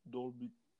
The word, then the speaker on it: Dolby: Dolby, Dolby Theater'da. Öyle mi okunuyor? Hmm. Aynen.